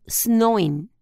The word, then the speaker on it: Samhain: conocido como Samhain y se pronuncian como Snowin,